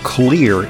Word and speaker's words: Xlear: He's CEO and founder of Clear Inc. That's spelled X-L-E-A-R. The X stands for xylitol,